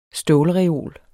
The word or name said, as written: stålreol